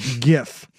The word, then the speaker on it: GIF: Hard G. Hard G. We don't go soft G. He should be in jail. – It's not a graphics interchange format. It's graphics interchange format. Hard G. GIF.